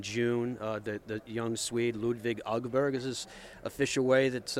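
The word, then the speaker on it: Åberg: It's all Berg, and everyone's calling him Aberg, but then many times the player himself just says, just call me Aberg, it's fine, and we'll just leave it at that.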